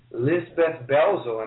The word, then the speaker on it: Lisabeth Belzel: And also, I have Miss Lisabeth Belzel.